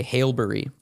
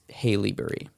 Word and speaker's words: Haileybury: a kid from haileybury which is a very difficult canadian town to pronounce because you want to say Haleberry, but it's Haleyberry.